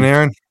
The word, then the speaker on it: Panarin: Um, a Terry, a, a, a what, what, what, how do I say his name? Panarin. – Panarin. Yeah. yeah.